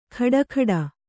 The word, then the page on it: ಖಡಖಡ: khaḍakhaḍa